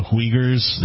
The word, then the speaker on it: Uyghurs: You know, talking about the am I pronouncing it right, the Uyghurs, the U-I-H-G-U-R. – Sounds good. Yeah.